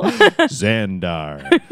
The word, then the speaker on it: Xandar: Xandar. That's how you say it.